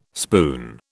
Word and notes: spoon: British English

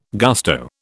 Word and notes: gusto: British English

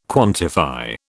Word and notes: quantify: British English

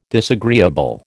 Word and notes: disagreeable: American English

